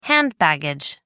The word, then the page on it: hand baggage: American English